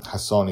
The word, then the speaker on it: Hasson: Hasson is spelled H-A-S-S-O-N.